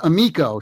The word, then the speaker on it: Amico: Well, and so I realize you don't know much about it, so I got to say it's pronounced Amico. See, now you just learned one thing. – Okay. – It's a good thing. This is why we're doing this. But amico is the Italian word for friend.